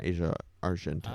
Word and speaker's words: Asia Argento: Have you seen the most recent news on Asia Argento? – I sure have. Yeah, you said it right.